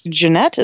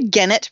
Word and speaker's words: Gennett: Jeanette, is that how you pronounce it? – Yep, Scooter Jeanette. – I was supposed to say Gennett for some reason, even though I know it's wrong. It's Jeanette, but, like, I want to pronounce it incorrectly, apparently.